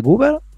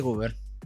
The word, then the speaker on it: Guber: En el, en el 56 escribe su segundo libro, Elementos de Cálculo Diferencial e Integral, en colaboración con la doctora, acá otra cosa a aprender a, pronunciar, Rebeca Cherep de Guber, de Guber, ¿cómo sería? Sí, así como suena. De Guber. Cherep de Guber.